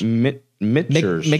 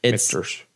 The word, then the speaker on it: Michter's: So I don't know how to say this, but is it Michter's? – Michter's. – It's a silent TH there. – Yep. – Michter's. – Michter's. Or H. Michter's.